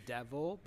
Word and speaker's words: Dev: Dev as in, I hate to say devil, but like, yeah, Dev as in devil.